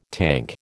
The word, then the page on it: tank: American English